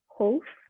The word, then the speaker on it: Howth: We actually got to uh, got to go a little bit outside and visit um, the coastal town Howth, oh, if I great.